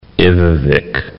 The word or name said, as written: Ivvavik National Park